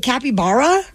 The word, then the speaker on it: capybara: Is it capybara? – Yeah.